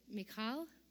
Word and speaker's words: Michal: How do you pronounce the wife of David? Is it Michal? Okay, thank you, Hebrew scholar.